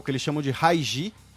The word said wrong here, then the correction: Hajj: É o que eles chamam de Hajj. H-A-J-J. Minha pronúncia árabe pode estar um pouco enferrujada.